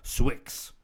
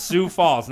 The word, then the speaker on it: Sioux Falls: And for the longest, I thought it was called Swix. Because it's spelled very. I'm like, yo, why don't you just do S-U-E, my guys? But it's Sioux Falls, not Swix.